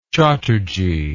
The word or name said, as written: chatterji